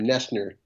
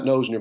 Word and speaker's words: Noesner: And by the way, you probably know this, but you pronounce my last name Noesner. – Noesner. – Not Noesner. But I only say it because I don't want the host to be embarrassed when they say, and today we've got Gary Noesner.